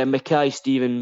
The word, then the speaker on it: Mackay Stephen: So, and just to check, and you've helped me out here, it's Mackay Stephen, not Mackay Stephen, like I originally said? – Yeah, Mackay Stephen would be the proper way of pronouncing it.